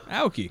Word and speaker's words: Aoki: Aoki.